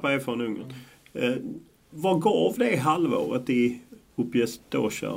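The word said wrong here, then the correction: Újpest: om jag uttalade rätt? – Ujpest. Ja.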